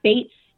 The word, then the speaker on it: Baetz: And then my Twitter handle is @fish with an uppercase F _ Bates.